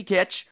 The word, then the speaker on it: Kitch: So everybody out there, check out Creepy Kitsch, and it's spelled K-I-T-C-H so that it rhymes with witch.